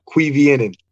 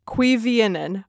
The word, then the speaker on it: Cuiviénen: Yes, uh, Cuiviénen. – Cuiviénen.